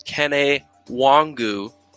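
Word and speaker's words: Kene Nwangwu: Kene Wongu is the pronunciation.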